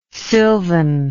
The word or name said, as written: sylvan